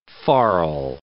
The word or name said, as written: farl